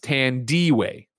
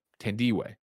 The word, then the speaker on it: Tandiwe: Mikey O writes in and says from the BBC, Tandy Newton reverts to the original spelling of her first name, Tandy, T-A-N-D-I-W-E, and is pronounced Tandiway, Tandiway.